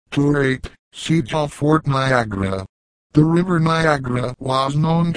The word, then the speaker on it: Niagara: The name Niagara is of Iroquois origin, and in the Mohawk dialect is pronounced Niagara.